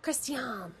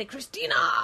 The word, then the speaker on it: Christiane: I would say Radio Christiane. – Christiane. I wanted to say Christina, but that's not how you spell Christiane. – C-H-R-I-S-T-I-A-N-E. Christiane. – Radio Christiane. – Radio Christiane.